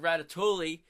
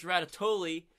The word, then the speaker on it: Ratatouille: I learned about Ratatouille, is it's not pronounced Ratatouille, it's pronounced Ratatouille.